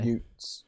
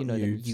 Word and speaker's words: utes: Utes, James. Not youths. – You know youths. Utes. – It's Y-U-T-E-S. You pronounce it utes.